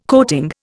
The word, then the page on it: coating: Indian